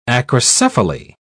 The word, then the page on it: acrocephaly: British English